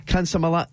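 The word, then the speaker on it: Consolmagno: So it's Father Guy Consolomano. Mm-hmm. The G is silent in his name.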